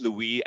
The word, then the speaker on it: Louis: Everyone pronounces differently, and I put Louis. I chose that to have like a little like French something in there, but I literally say like Louis.